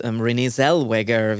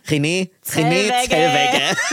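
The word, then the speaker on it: Renée Zellweger: Es gibt übrigens einen sehr, sehr sehenswerten Biopic über Judy Garland mit Renee Zellweger, wird sie ausgesprochen, die Bridget Jones, Renee Zellweger. Wir sagen Renee Zellweger bei Too Many Tabs.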